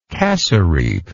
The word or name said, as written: cassareep